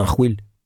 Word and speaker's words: Arwil: On prononce Arwil. Si on veut avoir l'accent, il faut appuyer sur le « il ». Ça signifie quelque chose comme coquin ou sacré numéro. Ah, lui, c'est un Arwil.